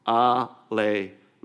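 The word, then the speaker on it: alelon (ἀλλήλων): As a matter of fact, I want you to say that with me, because I want you to remember this word, alelon. You ready? Alelon. Do we have it up there? Yeah, it's pronounced like this: alelon.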